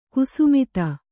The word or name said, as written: ಗೋಸಮಿತಿ